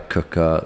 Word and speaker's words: Quooker: This podcast is sponsored by a cooker, spelt Quooker, pronounced Cooker, 100 degree broiling hot water.